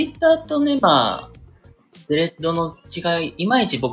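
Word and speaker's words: Threads: スレッドですね、スレッダー。スレッダーやって、スレッダーでよかったよね。未だにごめんなさい、読み方がわからんけど、あ、スレッドね、スレッドね、そうそうそう、スレッド。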